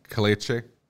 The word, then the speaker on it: Caliche: It looks like you actually have the pronunciation right. I was wrong. – How did I say it? – Caliche. – Is that it? Really? – Yeah.